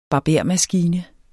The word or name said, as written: BARBERMASKINER